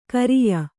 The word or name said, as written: ಖರಿಯ